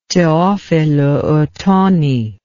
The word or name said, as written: Teofilo Otoni